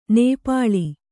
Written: ನಿಪ್ಪಲು

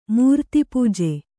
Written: ಮೂರ್ತಿಪೂಜೆ

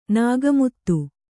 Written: ನಗೆಮಾತು

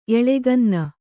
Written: ಎಳೆಗನ್ನ

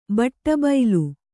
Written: ಬಟ್ಟಬೈಲು

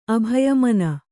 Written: ಅಭಯಮನ

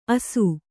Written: ಆಸು

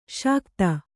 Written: ಸಕುತಿ

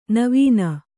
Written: ನೆವನ

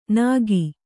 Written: ನಖಿ

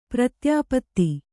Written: ಪ್ರತ್ಯಾಪತ್ತಿ

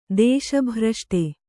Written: ದೇಶಭ್ರಷ್ಟೆ

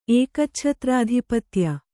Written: ಏಕಚ್ಛತ್ರಾಧಿಪತ್ಯ

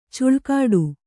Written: ಛಲಗೇಡಿ

